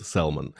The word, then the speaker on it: salmon: Раз уж речь зашла про английское произношение, то еще сразу русский человек выделяется, когда он произносит слово «лосось» по-английски, когда он говорит «salmon». А в английском языке буква «L» в слове «salmon» не произносится. Так же, как примерно мы не произносим букву «L» в слове «солнце».